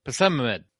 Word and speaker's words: Psammead: Now, how would you say it? P-S-A-M-M-E-A-D. Pessimamud.